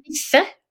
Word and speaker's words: Nisse: It's pronounced Nisse in Norwegian. And in Swedish, they call the exact same creature Tomte. – Okay. – T-O-M-T-E.